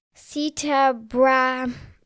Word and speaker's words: Sita Brahmachari: It is Sita Brahmachari.